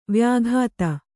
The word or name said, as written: ವ್ಯಕ್ತ